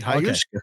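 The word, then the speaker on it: Ayahuasca: How do you say it, really? – You said it right. Hayayuska.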